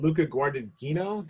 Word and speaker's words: Luca Guadagnino: And Luca Guadagnino, did I say that right? I didn't say it. Yeah. – Oh, okay, I got it right.